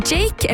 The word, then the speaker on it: JVKE: Han har ønsket seg en låta Golden Hour av artisten, som jeg tror uttales Jake. Det skrives I hvert fall J-V-K-E, så jeg litt usikker på hvordan det uttales, men det her en skikkelig god høstspillelistelåt.